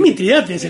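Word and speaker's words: Mitrídates: Yo estoy muy decepcionado que ahora pronuncian bien el Mitridates. – Mitridates. – Es Mitridates en la tortuga. – Sí, es Mitridates.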